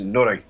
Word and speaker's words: Noroi: That's how they pronounce it, yes, Noroi.